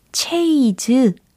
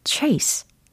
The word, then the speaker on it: chase: 요거 발음을 한번 해보실래요? 아, 체이즈 아니고요. 체스 아니고요. 오, 정답이 들립니다. chase, chase. 그렇죠. chase, chase 라고 하시면 뒤쫓다, 추적하다 라는 뜻이 됩니다.